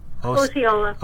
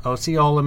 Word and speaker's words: Osceola: Osceola. – Osceola, Missouri.